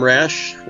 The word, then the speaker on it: Rasch: I am Tom Rasch, last name is spelled R-A-S-C-H, calling from Rapid City, South Dakota.